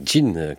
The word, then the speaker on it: Jean: Ce matin, une œuvre de Jean Coulthard, s'écrit Jean, mais c'est Jean, comme Jean Seberg, cette compositrice canadienne qui est née en 1908.